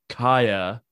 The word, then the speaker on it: Kjær: I tried to think outside the square a little bit there, but my other one I had was the Danish captain Simon Kjær, as he says it's pronounced.